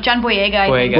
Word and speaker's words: John Boyega: How do you pronounce it? John Boyega? – John Boyega.